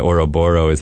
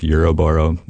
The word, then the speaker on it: Ouroboros: And so the Ouroboros is my take on, I believe it's pronounced Euroboro, the snake chasing its tail or consuming its tail.